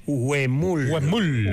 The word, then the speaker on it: Huemul: Creo que se pronuncia Huemul. – Huemul. Perfecto.